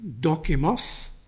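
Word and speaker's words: dokimos (δόκιμος): It's a word roughly pronounced as dokimos, and it was the title of a man in those days who would make sure the coin hadn't been tampered with.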